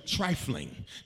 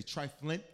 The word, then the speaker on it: trifling: It then means, watch these last two, trifling. Now, trifling is the appropriate pronunciation, pronunciation of the word. Some people say triflint. That's not the word. It's trifling, which means everything to you is trivial.